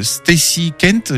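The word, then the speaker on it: Stacey Kent: Alors, un titre de Stacy Kent, j'espère que je prononce bien.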